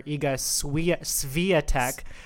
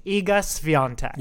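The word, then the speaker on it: Świątek: I kept on calling her Iga Swiatek. I thought I was being extra fancy. – Yeah, you're like that. There, it has that like accent on one of those vowels, and you're like, it sounds good. It looks good. – Yeah, and lo and behold, we were both wrong. Mm-hmm. It's Iga Swiatek. – Yeah. So when you pronounce it, and you think there should be an N there, and there's not, just go with it.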